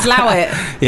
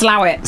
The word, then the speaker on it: Slaithwaite: Slawit. – Yeah. – It's spelled Slaithwaite. But it's pronounced Slawit.